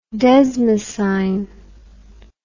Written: desmosine

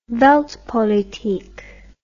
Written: weltpolitik

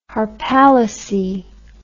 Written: harpalyce

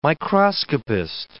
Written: microscopist

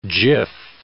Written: jiff

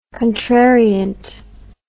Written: contrariant

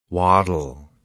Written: waddle

